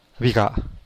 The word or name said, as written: bigaa